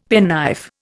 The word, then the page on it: penknife: Indian